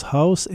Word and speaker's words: Huis: Ça s'écrit H-U-I-S, mais ça se prononce House.